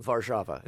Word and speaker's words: Warszawa: Warszawa.